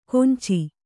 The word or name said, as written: ಕೊಂಚಿ